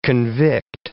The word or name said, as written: convict